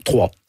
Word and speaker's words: Troyes: Vous commencez à jouer, bon, vous êtes né à Troyes, on dit Troyes en France, comment on prononce ? – Troyes. – Troyes, ben voilà, ça s'écrit YES en France.